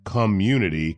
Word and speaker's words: ComeUnity: and it's spelled C-O-M-E-U-N-I-T-Y, Community Cafe.